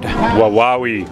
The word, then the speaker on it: Wawawai: Wawawi Road, I think. – You're correct. There's a couple different ways to get down there. The key road is Wawai Road. – Wawawi. – Thank you. It's a tough one to pronounce. – W-A-W-A-W-A-I.